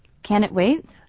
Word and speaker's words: can't it wait: 这个要怎么念啊？Can it wait？ – 哎，你听到没？那个 t 没有发音，变成 can 加上 it 连在一起，Can it wait？